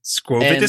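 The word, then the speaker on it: Skwovet: Squovit. – Squovit is correct.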